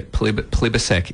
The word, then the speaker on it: Plibersek: So, yeah, I've actually received some criticism tonight on the Bigger Issues Twitter for not knowing who Tanya Plibersek is. – That's how you say it, yeah.